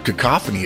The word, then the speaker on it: cacophony: Well, the word cacophony is a good one because most people don't know that that's how it's pronounced. C-A-C-O-P-H-O-N-Y.